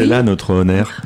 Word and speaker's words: On Air: Ça se prononce comme ça? – Oui, c'est comme ça. Bah, c'est comme la radio, tu sais, On Air, c'est le...